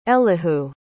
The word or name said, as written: elihu